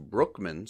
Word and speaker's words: Bruckman: Bruckman, I'm finding out just now, is actually pronounced Brookman, so Clyde Brookman.